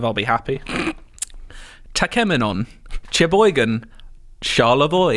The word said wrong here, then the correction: Cheboygan: Oh, well, you've already got one wrong. It's Cheboygan. – Is it Cheboygan? – I've heard of Cheboygan. – It's just a lot of soft C's in Michigan.